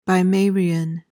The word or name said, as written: bimarian